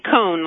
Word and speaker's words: Cone: Cone, like ice cream cone.